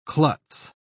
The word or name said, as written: klutz